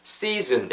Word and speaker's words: season: season 약간 z 이렇게 뱀 지나는 소리예요. 혀 끝이 이렇게 여러분 이랑 이 사이에 여기 여기 틈에다가 혀를 내시고 z z 뭐 감자는 것처럼 떨리죠. 이 느낌이 나셔야 돼요.